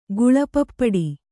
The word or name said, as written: ಗುಳಪಪ್ಪಡಿ